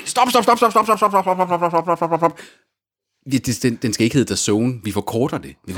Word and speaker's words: DAZN: Du får DAZN, som udtales DAZN. – DAZN.